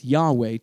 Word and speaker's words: Yahweh: When we pronounce the name as Yahweh today, it is truer to the original Hebrew, and this trend did not start in English until the 19th century.